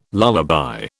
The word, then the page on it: lullaby: British English